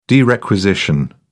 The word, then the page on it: derequisition: British English